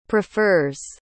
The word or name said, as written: prefers